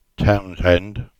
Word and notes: Townshend: English

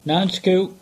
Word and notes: Nancekuke: English

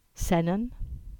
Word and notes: Sennen: English

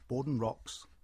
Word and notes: Bawden Rocks: English

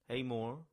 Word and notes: Heamoor: English

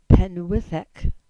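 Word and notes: Pennwydhek: Cornish

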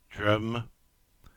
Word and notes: Drym: English

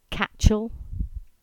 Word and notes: Catchall: English

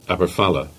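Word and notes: Aberfala: Cornish

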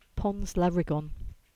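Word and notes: Lariggan Bridge: Cornish

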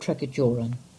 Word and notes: Tregajorran: English

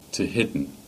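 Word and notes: Tehidy: Cornish

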